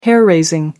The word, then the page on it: hair-raising: American English